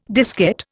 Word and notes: diskette: Indian

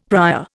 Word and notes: prior: Indian

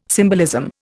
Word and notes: symbolism: Indian

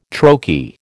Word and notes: troche: American English

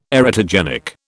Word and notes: erotogenic: British English

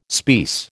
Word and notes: speiss: American English

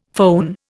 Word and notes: foehn: Indian

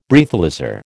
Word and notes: Breathalyser: American English

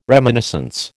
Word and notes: reminiscence: American English

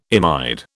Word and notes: imide: British English

